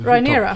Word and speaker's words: Rhaenyra: Ja, min favorit är ju Mille Alcock som spelar unga Reneris eller vad man ska... Hur, Rhaenyra. – Rhaenyra.